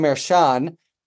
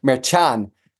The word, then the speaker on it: Merchan: If I can get nerdy for a second, because people in the courtroom call him Mershon, and he accepts that. But my understanding of the proper pronunciation, since he's Hispanic, is Merchan. And that's what I say, even though it's a little bit pretentious, but definitely both fly.